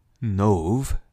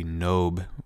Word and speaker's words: Nov: he comes across the city of Nov, is how you'd pronounce it in Hebrew, N-O-V. We have it as a B in English, so maybe Nob might be the better English pronunciation for it. But I'm going to call it Nov, to go with the Hebrew.